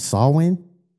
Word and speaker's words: Samhain: So the traditional origin, originated, god dang, with the ancient Celtic festival, and it's called Samhain, pronounced Samhain, but it looks crazy.